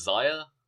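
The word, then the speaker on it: Xia: Then his secondary women were Lana Austin and I never know how to say this name, Zia Brookside? – I think it's Zaya. – Zaya? – Zaya, yeah. Brookside.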